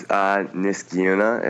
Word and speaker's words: Niskayuna: How do you pronounce that? – Oh, it's Niskayuna.